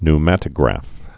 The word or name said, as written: pneumatograph